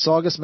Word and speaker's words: Saugus: Is that how you say it? S-A-U-G-U-S. It's Saugus? – Is it Saugus, Massachusetts? – I'd say Saugus.